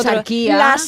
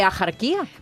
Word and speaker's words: Axarquía: Escribimos axarquía con X, sí. ¿Vale? Pero ¿cómo lo pronunciamos? ¿Axarquía? No, yo no conozco a nadie, axarquía. A mí que me registren, pero yo no conozco a nadie que diga axarquía.